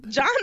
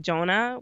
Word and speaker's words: Johna: No, I've actually gotten people who, whenever I say my name, they say Donna. I'm like, no, Johna.